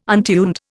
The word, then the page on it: untuned: Indian